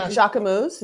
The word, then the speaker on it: Jacquemus: And even like Jacquemus, is that how you say it? – Yes.